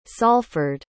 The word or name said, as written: salford